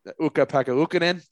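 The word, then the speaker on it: Ukko-Pekka Luukkonen: Uka Pekka Ukkonen. I think I probably butchered his name. You may correct. – That was not bad. That was pretty good.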